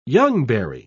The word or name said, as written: youngberry